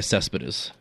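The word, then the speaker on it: Céspedes: Oh, so I say his name Cepedes? Is that how I say it? Because I'm in Canada? – No, Yoannis Cespedes.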